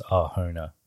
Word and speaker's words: Arjona: It's spelled with a J, but it's I'm pretty sure it's Arjona.